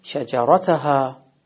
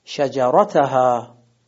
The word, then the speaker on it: شجرتها: پس حرف سوم که تکیه میگیره میشه نون خازنتها، و همچنین کلمه بعدی پنج حرفی هست شجرتها، حرف سوم میشه را، تکیه صحیح شجرتها. یه بار دیگه اینا تلفظ میکنم، احدکما خازنتها شجرتها.